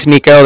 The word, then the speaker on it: Niko: Okay, like I said, my name's Nico. That's spelled N-I-K-O. – Uh-huh. – And then the Bank of Cardiff is spelled C-A-R-D-I-F-F, as in Frank.